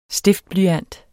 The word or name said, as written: stiftblyant